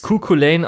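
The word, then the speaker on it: Cú Chulainn: Also ich weiß, Cuculain ausgesprochen und es wird auch ungefähr so geschrieben. Und es ist natürlich auch hier irische Mythologie und darum, das wird halt Skahach ausgesprochen.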